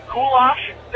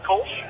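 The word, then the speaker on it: Kölsch: The Sensegel Matry, I don't know how the hell you got Kulosh there, Ed, but it is pronounced Kolsch, which is a German style, and...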